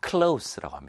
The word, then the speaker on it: close: close는 생김새는 하나인데요. 발음이 두 가지입니다. 쓰임에 따라서, 의미에 따라서 발음이 두 가지가 됩니다. 첫 번째는 뭐냐면 가까운이라는 의미일 때는 바로 close라고 합니다.